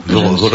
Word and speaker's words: Voronezh: Voronezh. Je ne sais pas si je prononce bien. – Voronezh.